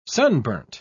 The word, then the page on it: sunburnt: British English